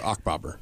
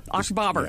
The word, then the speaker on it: Achbobber: If you just throw it at Achbobber. – Achbobber. Yeah. Achbobber.